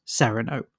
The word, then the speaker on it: Cyrano: Now, just to address the Cyrano thing, in this film, it is pronounced Cyrano.